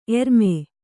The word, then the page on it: ಎರ್ಮೆ: erme